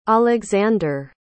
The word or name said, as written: Oleksandr